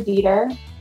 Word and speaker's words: Dieter: And just for listeners, Jennifer's last name is spelled D-I-E-T-E-R. So Jennifer Dieter.